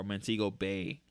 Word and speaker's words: Montego Bay: Next song is Don't Forget About Me and Montego Babe, or Montego Bay. That's how you say it.